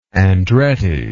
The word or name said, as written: Andretti